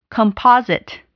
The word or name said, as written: composite